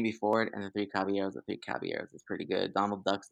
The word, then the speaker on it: Caballeros: Caballeros.